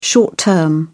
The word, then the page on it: short-term: British English